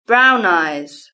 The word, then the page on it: brown eyes: British English